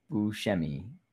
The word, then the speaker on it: Buscemi: Buscemi.